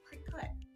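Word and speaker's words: Cricut: And they could be saying it wrong because it's C-U-T at the end. It's cut. It's cricket.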